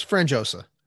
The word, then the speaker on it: Frangiosa: Is that, I hope I pronounced that correctly. I know it's Italian. – Yeah, it's close. Frangiosa.